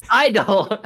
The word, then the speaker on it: I-DLE: It's just Idol. It's just Idol. The G is silent.